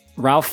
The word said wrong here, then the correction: Ralph: Fiennes villains for two. Rafe, I'm sorry. It's Rafe. That's how it's Fiennes? – Pronounced.